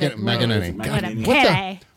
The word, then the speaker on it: McEnany: McInerney? Did I say that correctly? – McEnany, right? – I thought. – McEnany.